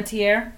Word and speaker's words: Panettiere: How about Hayden Panettiere? Is that how you say her name? – Hayden Panettiere.